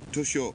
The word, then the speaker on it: Toyosu: Oh, at Tokyo's uh, Toshi, Toyosu Fish Market on on Sunday.